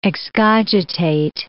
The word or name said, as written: excogitate